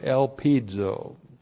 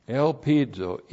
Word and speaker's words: elpizo: and then the verb elpizo. Elpizo, E-L-P-I-Z, long O, elpizo.